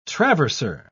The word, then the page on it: traverser: British English